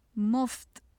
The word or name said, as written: muft